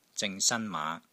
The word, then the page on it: ZING3 SAN1 MAA5: Cantonese